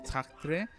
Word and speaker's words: Sartre: Mira, por ejemplo, hay una frase de Jean-Paul Sartre que dice, es Sartre, que según yo se pronunciaba como G, la R, bueno, Sartre, que dice la, dice, la libertad consiste en, en elegir, pues, qué hacer con lo que han hecho de nosotros.